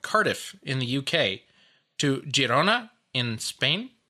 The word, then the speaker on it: Girona: And it is pronounced Girona, not Girona, because it is Catalan, not Spanish.